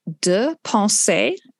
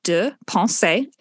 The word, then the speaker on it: Deux Pensées: I would like to suggest De Pensée Radio on Spotify. I'll say that again and I'll spell it. De Pensée. Yes, it is French. DEUX space PENSEES. De Pensée.